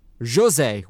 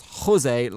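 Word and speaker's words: José: He says, "You're not the only people that do this, but Portuguese people don't do the silent J like Spaniards do. Jose even says it on the Amazon documentary. It's Jose with a soft J like the G in Rogue, not Jose like a garden hose.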